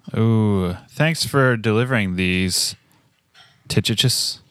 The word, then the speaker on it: Tychicus: Secondly, because Tychicus. – No. – How do you say it? – Tychicus.